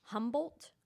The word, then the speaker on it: Humboldt: So it's a Native American tribe that live in the northeastern, and I'm going to butcher this word and I don't know how to say it. I'm so sorry. Humboldt County? – No, that's right, I think.